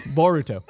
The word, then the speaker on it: Boruto: What is it? Burrito? Am I saying that right? – It's Naruto's son. – Yeah. Boruto.